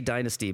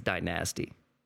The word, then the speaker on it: DyNAAAZty: No, it's people just say Dynasty, but it's spelled with a Z, so I'm sorry. Your name is DyNAAAZty.